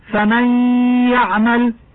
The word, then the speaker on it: فمن يعمل: إذا أردنا قراءة فمن يعمل فإننا نبدل النون الساكنة ياء ساكنة ثم ندغمها في ياء يعمل وننطقها حرفا واحدا مشددا من جنس الثاني فتقرأ فمن يعمل،